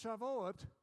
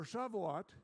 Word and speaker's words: Shavuot: Now that feast is Shavuot, or Shavuot. You can pronounce it the way you want to. That's how the Hebrew sounds, sort of.